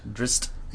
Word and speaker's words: Drizzt: Drizzt. – Yeah. – Because it's Drizzt. Yeah. Drizzt. Which is funny. – That sounds like a rapper if you say it that way. Drizzt.